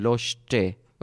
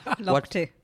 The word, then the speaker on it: Lochte: On top of that, I remember Abhinandan couldn't pronounce the name of swimmer Ryan Lochte. Okay, I'm still not doing it right. – Lochte.